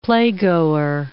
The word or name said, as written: playgoer